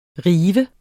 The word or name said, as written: rive